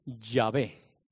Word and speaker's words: Yahvé: Que, por ejemplo, nosotros en España llamamos a Jehová, Jehová, pero en otros lugares le llaman Yahvé, o Yahvé, como yo lo puedo pronunciar, o Jehová, cuando se pronuncia en inglés. ¿Por qué? Porque una J en realidad es una Y.